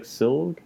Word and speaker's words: Silge: Siege. I don't know how to say her last name, actually, I'm realizing, but Silg, well, it's like Silg.